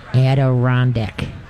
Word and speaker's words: Adirondack: They got all these Andorak, whatever they those kind of call it. Thank you. Andorak, what? I don't know how to say it. Adirondack? – Adirondack.